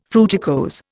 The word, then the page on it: fruticose: Indian